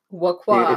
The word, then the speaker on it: La Croix: Water, IPA, and La Croix. – La Croix. – If you want to pronounce it properly.